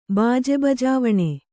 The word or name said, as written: ಬಾಜೆಬಜಾವಣೆ